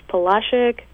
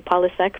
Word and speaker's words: Polasek: So I'm not sure if any of you guys are familiar with the, I'm sure I'm going to slaughter this, but Polashek Museum in Winter Park. – Yes. – I used to say Polasek, but I heard today that I was saying it wrong all along.